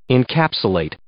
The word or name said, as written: encapsulate